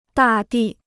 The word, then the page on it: 大地: dà dì